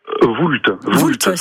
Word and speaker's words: Voulte: C'est un château, on dit le château de la Voulte, comment le prononce-t-on? – Voulte, c'est bien ça donc.